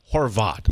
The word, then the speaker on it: Horvat: Like, he's such a nice guy that for all these years we were calling him Ryan Horvat. It's Horvat. – Using our hard A accent here from Western New York.